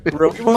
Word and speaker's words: Rogue One: Rogue One.